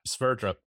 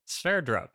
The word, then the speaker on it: Sverdrup: How do you say that? – Sverdrup. – Sverdrup. – Well, I think that's right.